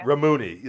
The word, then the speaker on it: Rammuny: Rammuny. – Okay. I said Rammuny. – I can't even have you roll the R's, even though so many people can pronounce fucking Russian names all the time, but nobody can pronounce Middle Eastern ones.